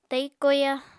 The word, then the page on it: tai koya: apatani